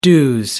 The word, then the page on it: dues: British English